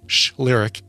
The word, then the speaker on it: Shlyric: It's spelled just like it sounds, S-H-L-Y-R-I-C, Schlyric, as in shh, there's no sound, lyric.